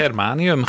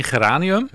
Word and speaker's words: Germanium: Germanium, geen geranium. – Nee, bijna geranium, maar dan germanium.